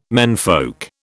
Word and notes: menfolk: British English

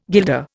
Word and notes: guilder: Indian

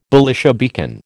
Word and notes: Belisha beacon: American English

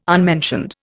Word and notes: unmentioned: Indian

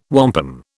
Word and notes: wampum: British English

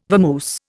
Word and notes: vamoose: Indian

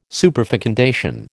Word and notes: superfecundation: American English

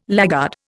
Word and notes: laggard: Indian

